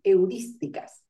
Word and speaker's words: heurísticas: Heurísticas. Perdón, no me salió el acento, lleva acento en la I, heurísticas.